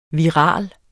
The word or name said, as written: viral